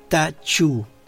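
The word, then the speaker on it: Ta Ch'u: Y el último de estos 3 hexagramas relacionales es Ta Chu, que se escribe Ta separado CH'U, y significa el poder domador de lo grande.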